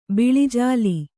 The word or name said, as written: ಬೆಳ್ಳಿಜಾಲಿ